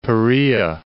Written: Perea

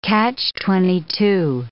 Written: catch-22

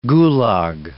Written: gulag

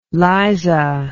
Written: Liza

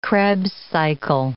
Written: Krebs cycle

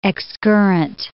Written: excurrent

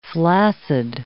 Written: flaccid

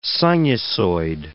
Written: sinusoid

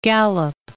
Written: Gallup

